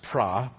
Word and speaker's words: pro: Pro, it's pronounced pra, but don't let that settle, which simply means before.